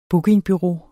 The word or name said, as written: bookingbureau